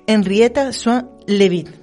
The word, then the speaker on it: Henrietta Swan Leavitt: Henrietta Swan Leavitt.